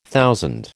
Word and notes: thousand: American English

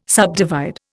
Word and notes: subdivide: Indian

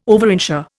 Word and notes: overinsure: Indian

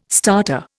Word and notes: starter: Indian

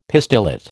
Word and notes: pistillate: American English

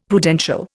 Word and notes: Prudential: Indian

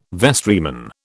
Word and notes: vestryman: British English

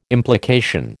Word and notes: implication: American English

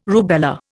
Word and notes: rubella: Indian

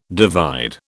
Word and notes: divide: British English

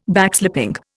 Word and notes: Backslapping: Indian

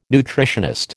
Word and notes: nutritionist: American English

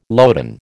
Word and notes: loden: American English

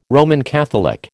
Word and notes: Roman Catholic: American English

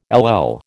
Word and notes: LL: American English

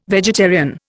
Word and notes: vegetarian: Indian

